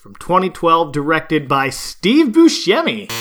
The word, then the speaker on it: Buscemi: Directed by Steve Buscemi.